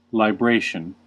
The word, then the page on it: libration: American English